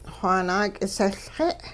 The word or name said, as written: xwaa naak'isehLxe'